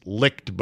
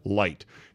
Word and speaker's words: Licht: But good for Jason Licht, who is spelled L-I-C-H-T. It's licked, but it's really pronounced light.